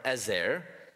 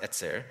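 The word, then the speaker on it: etzer (עזר): it's the Hebrew word etzer or ezer. In Hebrew, it's pronounced etzer.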